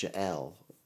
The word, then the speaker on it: Yael: It was spelled really weird, but because it was like Y-A-E-L, but she's like, it's pronounced Jael.